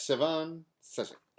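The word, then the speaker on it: Stjepan Šejić: Stefan Sejic. – Yes. And so I'm not pronouncing the J. Yes. Stefan Sejic.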